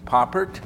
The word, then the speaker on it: Pouppirt: And my last name is spelled P-O-U-P-P-I-R-T, but we don't pronounce it French because then it's Poopert. And when they came over here, it was originally Huppert, H-O-U-P-P-E-R-T-T-E.